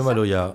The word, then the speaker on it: Maloya: Reste là, Maloya. – C'est ça, c'est le ça Maloya.